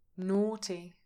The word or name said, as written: naughty